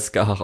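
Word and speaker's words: Scáthach: Und es ist natürlich auch hier irische Mythologie und darum, das wird halt Skahach ausgesprochen.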